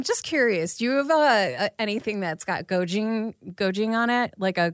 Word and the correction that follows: gochujang: Oh, like like gochujang?